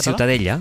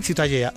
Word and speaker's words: Ciutadella: ¿cómo se pronuncia? – La catalán? Ciutadella. – Ciutadella, Ciutadella. Bueno, pues Ciudadela también en castellano.